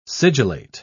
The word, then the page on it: sigillate: British English